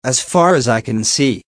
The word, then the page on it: as far as i can see: American English